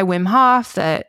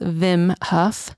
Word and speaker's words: Wim Hof: And there's this guy, Wim Hof, it's actually pronounced Vim Huff.